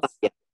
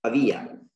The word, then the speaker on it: Pavia: Not Pavia. It's Pavia in Italian language. You tell Pavia, but it's Pavia.